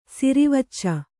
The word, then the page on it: ಸಿರಿವಚ್ಚ: sirivacca